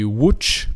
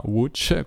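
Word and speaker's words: Łódź: Wuch, che si scrive L-O-D-Z, e per qualche misterioso motivo non si legge Lodz, ma Wuch, questa è la pronuncia del polacco.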